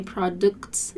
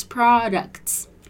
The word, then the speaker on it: products: We not only have good products, be careful you don't say products, it's products, but our customer support is also way ahead of the competition.